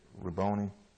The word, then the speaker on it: Rabboni: I think that's the way you pronounce that, Rabboni, which is to say teacher.